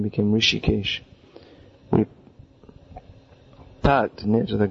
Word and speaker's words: Rishikesh: Now, often devotees, they mispronounce this as Rishikesh. And some people say that the name has derived from the name of the Supreme Lord, Hrishikesh, beginning with an H. Actually, the name is Rishikesh.